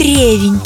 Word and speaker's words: ревень: Ревень – только такое ударение и должно быть в этом слове. А вариации на тему «пирог с чем? С ревнем? С ревенем? Или с ревенем? Или с ревнем?» Ревнем, кстати говоря, тоже говорят. То есть либо ревенем, либо ревнем. – Это тоже допустимая форма. И есть хорошая запоминалочка для тех, кто все время путает, где там ставится ударение. Друзья из деревень Приехали поесть ревень.